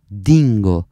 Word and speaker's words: Dingo: Dingo, che quando parlo magari lo vedete scritto in un altro modo, in italiano lo pronunceremo Dingo, con la D.